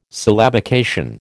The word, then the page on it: syllabication: American English